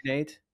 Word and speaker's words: threonate: Threonate? – Yeah.